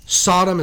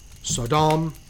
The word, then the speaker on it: Sodom: Now, we'll talk about Sodom for a second. Sodom is the most common pronunciation. Sodom is another pronunciation. Sodom is another pronunciation. All three are correct,